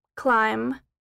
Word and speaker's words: climb: In general, the B isn't pronounced when it follows an M. Comb, limb, dumb, bomb, climb, tomb, thumb.